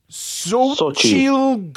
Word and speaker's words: Xochitl: Xochitl Gomez.